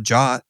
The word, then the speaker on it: JWT: And so people call it a jot, right? Because JWT is a mouthful.